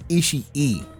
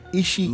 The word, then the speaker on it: Ishii: It's not Ishii. It's Ishii. Ishii. Look. Every syllable is pronounced. Every letter, rather.